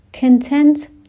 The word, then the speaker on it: content: Content به تلفظش دقت بکنین، نمیگم content. این کلمه دو تا تلفظ داره و معانی مختلف میگیره با تلفظهای مختلف. اگر بگم content به معنای محتوا هست. اگر بگم content که استرس میاد روی بخش دوم کلمه، به معنای خوشحال و راضی هستش.